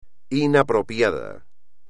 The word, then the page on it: inapropiado: espagnol